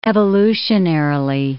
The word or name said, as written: evolutionarily